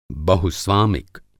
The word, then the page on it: बहुस्वामिक: Hindi